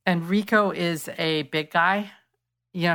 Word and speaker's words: Reco: His name is Rico, but it's spelled R-E-C-O.